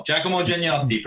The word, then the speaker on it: Giacomo Giannotti: Giacomo Giannotti.